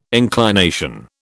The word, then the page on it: inclination: British English